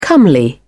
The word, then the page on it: comely: British English